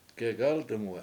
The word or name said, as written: gegAltumwe7